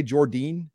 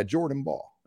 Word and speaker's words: Jordyn Bahl: Okay, but, I'm just trying to get up well, to speed here because I've never heard anyone say Jordine. – Well, it's spelled J-O-R-D-Y-N. – Yeah, Jordan Ball.